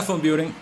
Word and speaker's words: von Büren: El, apellido es alemán, entonces yo no sé hablar alemán, así que tal vez yo tampoco lo pronuncie bien. Es von Buren. – Ok, Adriana von Buren, hoy con nosotros desde el staff.